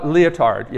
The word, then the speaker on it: Lyotard: He's talking about a guy, Jean Francois Lyotard. Yeah, just like the thing that you might wear if you're a dancer. Um, that's how you say it.